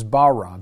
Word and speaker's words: bara: So the Hebrew word for create in Genesis 1:1, okay, is bara, B-A-R-A, in English.